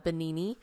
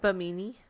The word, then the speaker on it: Bimini: Is it Bimini? – Now I think it's not.